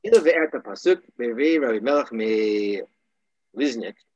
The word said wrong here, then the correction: Lizhensk: that's the name. Lizhensk.